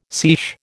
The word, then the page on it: seiche: American English